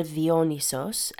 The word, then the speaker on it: Dionysos: So Dionysos, or Dionysos, as his name is pronounced in Greek,